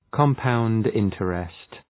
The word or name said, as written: compound interest